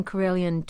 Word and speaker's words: Karelian: Is that, am I saying that correctly? Karelian tradition? – Karelian, yeah.